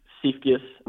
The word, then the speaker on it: Siefkes: So it's at Coach Seifkes, and then the last name is spelled S-I-E-F-K-E-S.